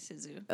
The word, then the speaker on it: Cixous: something that I agree with, I forget how to pronounce her last name because it's French, Helena Cixous? – If there's an X in the middle of it, it's probably Cixous. – Okay, it's Cixous. – I think that's Cixous.